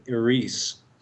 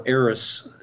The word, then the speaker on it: Eris: Eris, or Eris, as it's pronounced differently by other people.